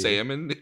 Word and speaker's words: salmon: It's salmon, you degenerate. – It's salmon. – It's salmon. – It's spelled S-A-L-M-O-N, salmon. – So it's pronounced, it's not. – It's spelled P-S-E-U-D-O-N-Y-M, but it's still pronounced pseudonym.